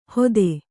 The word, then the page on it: ಹೋತೆ: hōte